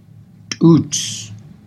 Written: tuuj

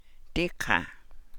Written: dii ḵaa

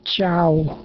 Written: chaaw

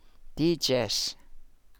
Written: dii jas

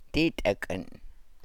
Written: dii takan

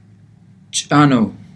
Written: tsaanuu

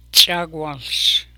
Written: Tsagwaals

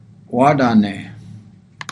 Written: waadaa nee

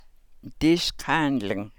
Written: Dii sḵaanlang

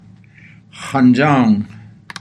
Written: xanjaangw

